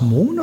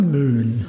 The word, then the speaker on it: Moen: Ähm, und natürlich hat äh, der Sondre, heißt er nicht wie spricht man ihn aus? Mohn oder Möhn? Sondre Mohn. M-O-E-N.